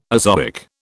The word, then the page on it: azoic: British English